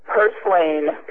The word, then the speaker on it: purslane: Purslane is how they pronounce it, I think. – One more time. – Purslane. P-U-R-S-L-A-N-E.